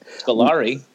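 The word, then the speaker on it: Scolari: And Peter Sclarelli. That's how you say it. Sclarelli.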